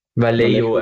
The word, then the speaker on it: Vallejo: Ich hätte irgendwie auch so um die 150 oder sowas gesagt, weil ich mittlerweile auch vor allem mit Vallejo oder Vallejo, wie spricht man es aus? Ich weiß es nicht genau. – Vallejo eigentlich. Vallejo. Naja, also Doppel-L spricht mir, glaube ich, eigentlich als J.